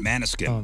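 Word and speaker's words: Måneskin: Is this Manskin? Manskin? – Manaskin.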